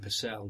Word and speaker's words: Purcell: And this pal of mine, who I knew from when I used to live around Hampstead, the first assistant director called Mike Purcell. The English called him Purcell, but his Irish pronunciation is Purcell.